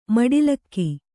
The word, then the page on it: ಮೆಟ್ಟಲಿಗೆ: kannada